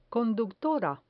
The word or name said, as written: conductora